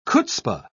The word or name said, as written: chutzpah